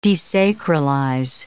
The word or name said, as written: desacralize